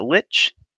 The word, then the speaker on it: Lich: That is, did you pronounce that Lich? – Yes.